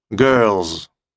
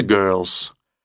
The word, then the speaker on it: girls: Много девушек — girls или girls. Они произносят и S, и Z в конце.